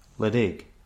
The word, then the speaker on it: Ledaig: is known as Lydaig. And it's pronounced Lydaig. – Did you spell it? – L-E-D-A-I-G.